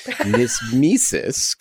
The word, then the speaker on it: knismesis: One that's very hard to pronounce. Nismesis, K-N-I-S-M-E-S-I-S, is a soft, gentle kind of tickling, like when somebody strokes you with a feather or an insect crawls across your skin.